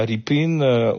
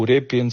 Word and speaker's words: Repin: si vous connaissez cette fameuse tableau de Ilya Ripin ou Repin selon les prononciations,